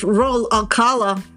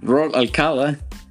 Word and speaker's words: Raúl Alcalá: No, pronunciado en inglés australiano es Roll Alcala. – Roll Alcala.